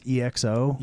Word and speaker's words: EXO: So clarify, it's EXO.